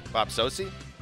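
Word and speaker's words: Bob Socci: Bob Sochi? – I thought you just got it right the first time.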